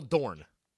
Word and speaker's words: Dorn: Michael Dorff has more on-screen appearances playing what character than any other in the Star Trek franchise universe? Michael Dorn.